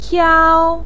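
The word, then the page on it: piao1: tone 1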